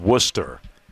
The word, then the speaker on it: Worcester: And I do like, again, I said this earlier in the show, but right there on the pronunciation, it is Worcester.